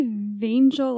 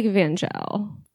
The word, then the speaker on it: Vangel: I would say Vangel, like angel with a V, personally. – I like Vangel.